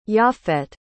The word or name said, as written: japhet